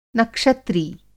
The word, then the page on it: नक्षत्री: Hindi